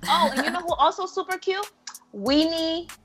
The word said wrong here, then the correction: Winnie Harlow: Oh, am I saying her name? – Winnie Harlow. – Like, there you go.